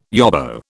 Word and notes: yobbo: British English

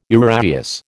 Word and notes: uraeus: American English

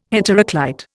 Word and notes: heteroclite: Indian

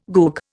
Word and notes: goog: Indian

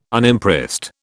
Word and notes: Unimpressed: British English

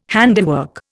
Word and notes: handiwork: Indian

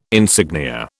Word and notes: insignia: British English

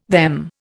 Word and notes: them: Indian

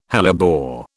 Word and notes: hellebore: British English

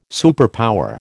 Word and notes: superpower: American English

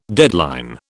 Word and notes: deadline: British English

